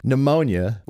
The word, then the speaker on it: pneumonia: The one that I think that is fun that is not a typo, pneumonia, with a P, has a P in front of it, a silent pneumonia. – P, like pterodactyl.